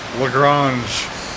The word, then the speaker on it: Lagrange: And he just laughed at me and he goes, I know you're from Texas, but you have to give some sort of effort in pronouncing the French, like Lagrange.